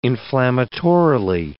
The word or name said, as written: inflammatorily